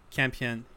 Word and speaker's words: Campion: And the final film of the January releases is The Piano by by Jane Campion. I think that's how you say it. – Campion.